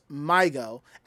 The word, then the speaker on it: MyGO: The name is pronounced MyGo, and its pronunciation can be meant a lost child.